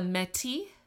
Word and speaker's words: Métis: And then I'm going to mention, because this comes up later in the story, the Métis. I don't think the S is pronounced. So it's M with an E and a little accent mark, T-I-S.